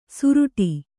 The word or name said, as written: ಸುರುಟಿ